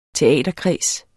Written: teaterkreds

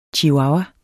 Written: chihuahua

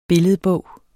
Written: billedbog

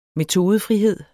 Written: metodefrihed

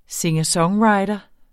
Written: singer-songwriter

